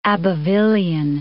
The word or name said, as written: Abbevillian